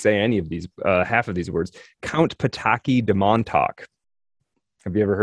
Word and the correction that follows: Potocki: So it was probably Potocki then. – Potocki? Oh, okay. Okay. See, I figured I was pronouncing that part wrong.